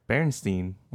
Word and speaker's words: Berenstain: Berenstain.